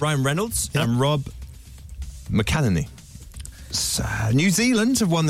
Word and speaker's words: McElhenney: And it's Rob McElhenney.